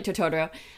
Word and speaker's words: Totoro: So like Totoro.